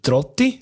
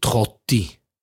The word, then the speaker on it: Trotti: Zum Beispiel, wenn sie plötzlich wie aus dem Nichts bei einem Wort der R anders aussprechen. Aber nur bei dem einen Wort. Dann heisst es zum Beispiel plötzlich nicht mehr Trotti, sondern Trotti.